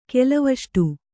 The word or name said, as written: ಕೆಲವಷ್ಟು